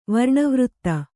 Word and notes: ವರ್ಣವೃತ್ತ: varṇa vřtta

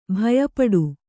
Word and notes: ಭಯಪಡು: bhaya paḍu

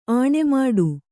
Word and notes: ಅಣಿಮಾಡು: aṇimāḍu

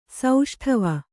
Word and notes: ಸೌಷ್ಠವ: sauṣṭhava

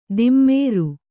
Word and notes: ದಿಮ್ಮೇರು: kannada

